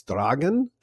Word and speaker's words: Dragan: His name is Dragan, and I'm doing my best to pronounce it correctly. It is spelled exactly like dragon, except with an A at the end instead of an O.